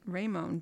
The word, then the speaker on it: Raymone: Wait, LeBron Ramon. – Oh, Raymond. – Raymond. James Sr. Emphasis on the Ray. – Yeah. R-A-Y-M-O-N-E. Raymond. James Sr. Yeah, December 30th.